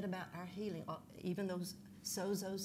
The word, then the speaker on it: sozo: The definition for saved is the word for saved in the Greek is S-O-Z-O, pronounced sozo.